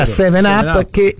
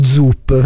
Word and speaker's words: 7-Up: Però ti posso dire che uno sponsor della Fiorentina, che se lo ricorderanno tutti, fu una bibita che all'inizio non sapevamo come si pronunciasse, che era 7-Up, e noi da ragazzi la prime volte, scusi, mi dà uno Zup, perché sembrava con la Z, no? E invece era un 7, e noi all'italiana...